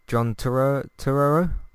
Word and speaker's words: Turturro: John Turturro? – Right.